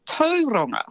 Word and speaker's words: Tauranga: And Tauranga as well is actually Tauranga.